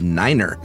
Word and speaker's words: niner: Most numbers are the same with a notable exception of niner. We use niner instead of nine, because over garbled radio, it sounds like five, but mainly because NATO countries speak German, nine means no in German.